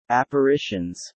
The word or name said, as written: apparitions